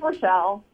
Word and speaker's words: Rachelle: It's Rochelle. You do that every time, but it's okay. – Well, sorry. – Way to go, Mark. – I mean, it's spelled Rachel. Talk to Justin. I can't. – Really? Because I spelled it for him, too. – How do you spell it? – R-A-C-H-E-L-L-E. – Oh, well, see, that looks like Rachel to me. – No, that looks like Rochelle.